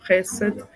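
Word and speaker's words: chesed (חסד): Zatímco Mill-L je, když se akcentuje jiná slabika než ta poslední, takže to ha, he, sed, jo? Dávár a chesed. Jo, chesed, tam je ta předposlední vlastně.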